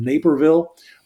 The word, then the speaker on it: Naperville: A reminder, that course is April 12th in Naperville. Rhymes with Paperville and Caperville and Vaporville and lots of other things.